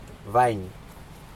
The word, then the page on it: vaiN: Aneityum